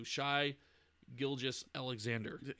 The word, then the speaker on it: Shai Gilgeous-Alexander: Patrick Beverley, Jason Tatum, Chris Middleton, and I'm going to butcher this one too, Shai Gilgeous-Alexander.